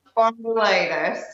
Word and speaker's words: spondylitis: Spondylitis, yeah.